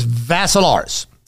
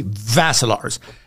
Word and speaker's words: Vassilaris: Dimitri with three I's, D-I-M-I-T-R-I. Picture a Cyclops, a second Cyclops, and a third Cyclops. Dimitri with three I's. The last name is Vassilaris. It rhymes with Asilaris, but you add a V to it. So Dimitri Vassilaris.